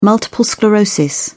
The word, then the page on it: multiple sclerosis: British English